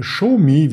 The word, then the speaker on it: Xiaomi: mit der Alibaba und mit der Xiaomi, wie es ausgesprochen wird.